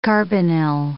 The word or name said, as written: carbonyl